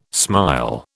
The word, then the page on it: smile: British English